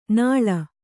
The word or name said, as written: ನಾಳ್